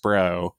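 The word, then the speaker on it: SPR: So, I mean, the one thing they can do is release everything in the SPRO, in the Strategic Petroleum Reserve, which is ridiculously pronounced SPRO.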